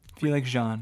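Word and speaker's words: Felix Jaehn: The song gained widespread popularity thanks to its, is it Felix Jean? Is that how we say his name? – Felix Jean.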